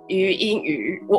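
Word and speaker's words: 禹英禑: OK，好，那今天我们要前面有讲到，我们今天要来聊，就是最近最近很红的一些一些法庭剧，然后那个最近很红的，好像有一出叫做《非常律师》，呃，于英语，我我这样念对吗，Sophie？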